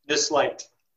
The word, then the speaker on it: Nisleit: This Light. – This Light. Okay. It's spelled N-I-S-L-E-I-T.